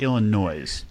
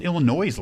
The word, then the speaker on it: Illinois: And Jim, I was taking notes there, and I wrote Illinois. There's an S on the end, right? – There is, in fact, an S, but it is silent. S for silent.